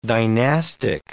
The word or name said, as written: dynastic